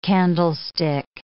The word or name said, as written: candlestick